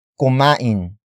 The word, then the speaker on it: kumain: For example, saan kumain si Celine? Saan kumain si Celine? Saan kumain si Celine? It means, where did Celine eat? Word for word, it's saan kumain si Celine.